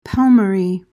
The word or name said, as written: palmary